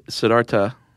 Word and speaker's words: Siddhartha: Actually, Siddhartha. – Okay, so no, the H is silent.